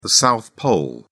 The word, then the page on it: South Pole: British English